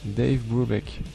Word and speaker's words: Dave Brubeck: Alors maintenant, The Dave Brookett Quartet. Est-ce que je l'ai bien prononcé ? Dave Brubeck. Ouais, Dave Brubeck Quartet.